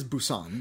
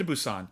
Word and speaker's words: Busan: And it's the kind of thing where, ultimately, Train to Busan is... And I apologize if I'm pronouncing that right. – I believe it is Busan. But okay, yeah. Train to Busan.